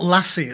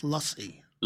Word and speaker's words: lassi: It's not lassi. It's not the dog. – What? – although it's spelled mangoes. – Yes, I know it's spelt like the dog, but it's a sound. It's lassi. – Lassi. – Like a U.